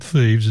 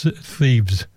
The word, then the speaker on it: Thebes: Thebes is spelled T-H-E-B-E-S. It's not Thebes, it's Thebes, Thebes.